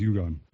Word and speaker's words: Egon: We're going to show you the little girl, Egon? Is it Egon? – Egon is Egon. – Yeah, if Peter heard me mispronounce Egon.